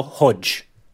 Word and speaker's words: Hodeg: As we speak, the first stage has been run off and won by Álvaro Hodeg. – Álvaro Hodge. – Well, a timely intervention there from the pronunciation police.